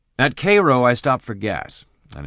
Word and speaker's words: Cairo: I headed for Cairo, which is spelt the same way as Cairo, but is pronounced Cairo. I don't know why.